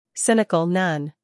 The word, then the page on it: cynical: ˈsɪnɪk(ə)l